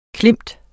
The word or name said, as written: klemt